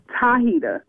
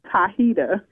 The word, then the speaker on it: Tahita: Am I pronouncing that right? Hi, Heater. – Tahita with a T. – Oh, Hi-Hita. All right, baby. – Tahita.